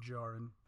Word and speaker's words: Djarin: and which is Din Djarin, but the D is silent, so it's Din Djarin.